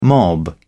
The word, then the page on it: mob: British English